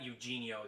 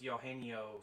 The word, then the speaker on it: Eugenio: It's not Eugenio, it's Eugenio.